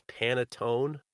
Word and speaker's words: panettone: So he's known for making these, I think the pronunciation is panettone.